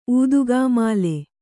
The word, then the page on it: ಊದುಕಾಮಾಲೆ: ūdukāmāle